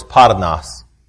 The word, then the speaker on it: pornos: It's spelled P-O-R-N-O-S. The proper pronunciation for the word is parnas.